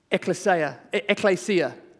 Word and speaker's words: Ekklesia: This word church in the New Testament comes from the Greek word Ekklesia, that's how you pronounce it, ekklesia.